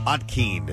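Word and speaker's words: Ontkean: Michael Ottkean is how you say his last name.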